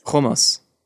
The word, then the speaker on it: hummus: Anyways, fine. Hummus. – Hummus. – That's how it's pronounced.